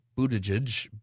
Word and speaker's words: Buttigieg: Well, we got an interesting suggestion today from our transportation secretary, Pete Buttigieg. That's how they say you should say his name.